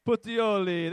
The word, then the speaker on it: Puteoli: Puteoli.